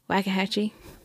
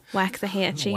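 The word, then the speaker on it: Waxahachie: Wakahatchee? – Wakahatchee. – Yeah. – And then when you read it, you're just like, Waka, what? – Waxahatchee.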